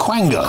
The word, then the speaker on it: quango: People like Kemi Badenoch have said it's total madness from another unaccountable Quongo Quango. How do you say it? – Quango. – Quango, of course you do.